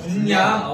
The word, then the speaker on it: 분량: 아니 그러니까 불량이 그만큼 많이 뽑힌다. – 그래서 아. 불량이다. 얘네 자체가 아. 예, 존재 자체가 존재 자체가 불량이 있다. 예. 불량이 아니라 불량 그렇죠. 분량.